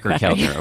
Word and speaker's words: Keltner: I don't know how you pronounce his name. – It rhymes with cracker. Dacker Keltner.